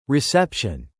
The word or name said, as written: reception